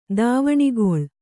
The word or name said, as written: ದಾವಣಿಗೊಳ್